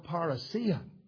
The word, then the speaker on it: parousia: It is the Greek word parousia.